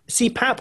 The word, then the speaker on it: CPAP: So describe for those that don't know, like myself, what is a CPAP? CPAP or CPAC? – CPAP. – CPAP. – It's spelt as it sounds, the letter C, P-A-P.